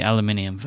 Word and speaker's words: aluminium: Um, and I have the aluminium version, um, pronounced correctly, of course.